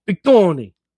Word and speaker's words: Big Tony: Yes, and you have to say his name like Bigoni, like Bigoni. Like it's like one word, but it's two words.